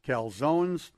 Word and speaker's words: calzone: Pizza, pasta, calzones. Uh, calzone, is it calzone or calzone? Uh, you don't pronounce the E, so it's You don't calzone.